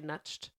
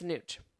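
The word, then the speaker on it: Nutsch: I had to write the how to say it because it's N U T S C H, and I want to say nutched, but that's not what it is. It's Nooch, Mark Nooch.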